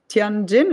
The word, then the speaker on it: Tianjin: Tianjin is that pronounced right? it's yes.